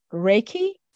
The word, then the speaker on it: Reiki: What is Reiki spirituality? – So you have pronounced it correctly. Thank you.